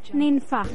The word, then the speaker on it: Ninfa: T-O-N-Y. – And your name? – Ninfa. – Ninfa, can you spell that? – N-I-N-F-A.